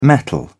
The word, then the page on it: mettle: British English